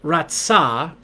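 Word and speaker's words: ra tsa (רצה): The Hebrew word ratsa, pronounced ra tsa, ra ra ri, kick him in the tsa, comes with the connotation of understanding and acceptance.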